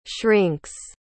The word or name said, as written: shrinks